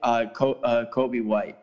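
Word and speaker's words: Coby White: I like Kobe White. That's his name, right? Kobe, mm-hmm. I said that right.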